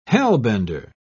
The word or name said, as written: hellbender